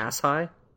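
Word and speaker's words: Asshai: So, from that, Dany understands that she needs to go to the third one was the correct pronunciation. – Ashai? – Yes.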